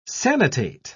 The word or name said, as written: sanitate